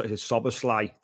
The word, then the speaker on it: Szoboszlai: It's Szoboszlai.